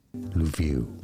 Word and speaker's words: Lviv: Lviv.